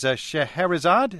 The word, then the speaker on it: Scheherazade: This is Scheherazade.